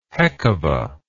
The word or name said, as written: heckuva